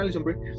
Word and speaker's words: Alison Brie: Ah, sí, sí, lo dije bien, Alison Brie.